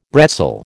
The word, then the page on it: bretzel: American English